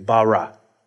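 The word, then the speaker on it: bara (ברא): The word bara, B-A-R-A. If you're going to pronounce it, it'd be like B-A-W-R-A-W, bara, right?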